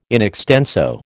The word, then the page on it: in extenso: American English